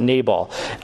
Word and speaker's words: Nabal: And then there was the story of Nabal, as we sometimes pronounce it, Nabal, Abigail's first husband, the great fool, which his name actually means fool, which is great.